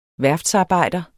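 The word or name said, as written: VÆRFTSARBEJDER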